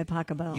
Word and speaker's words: Pachelbel: Canon D by Pasquale. – By Pachelbel. – You pronounce it Pasquale? – No.